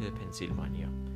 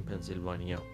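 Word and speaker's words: Pennsylvania: ایالت پنسیلوانیا در زبان انگلیسی حالا ممکنه این نمیدونم در زبانهای اروپایی به این شکل تلفظ بشه ولی در انگلیسی به هیچ عنوان این شکلی تلفظ نمیشه و این پنسیلوانیا هستش پس آنچه ما میگوییم پنسیلوانیا آنچه آنان میگویند پنسیلوانیا آنچه میتوان گفت پنسیلوانیا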